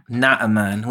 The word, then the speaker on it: Naaman: I'm going to say Naaman. – Naaman, which is the correct way to say that.